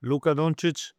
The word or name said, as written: Luka Doncic